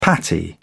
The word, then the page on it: patty: British English